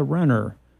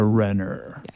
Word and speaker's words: Renner: and so I started saying it's Renner. It rhymes with winner, not with wiener.